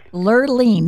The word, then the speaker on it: Lerlean: Lerlean. Now, that is a cool name. Isn't that cool? – How do you spell that? That's kind of cool. – It's L-E-R-L-E-A-N, Lerlean.